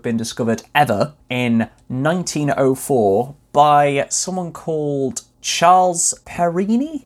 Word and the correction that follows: Perrine: P E R R I N E, Perini, Perrine. How would you say that? – Yeah, Perrine. – Perrine. He was born in America, so I don't think it would have been a French pronunciation.